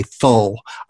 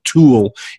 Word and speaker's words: Thule: An offshoot of the Vril Society that was called I want to say Thull. I'm never going to pronounce that correctly. You know, somebody who speaks German would probably have a better grasp on how the name is pronounced, but say like, or Tool, it may be pronounced like the Tool Society, T-H-U-L-E, which was a branch It was like a schism from the Vril Society.